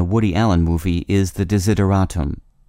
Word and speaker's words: desideratum: The Word of the Day for June 26th is desideratum, spelled D-E-S-I-D-E-R-A-T-U-M.